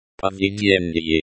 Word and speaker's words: поведение: The stress in the word. Поведение. Поведение. Is on the third syllable.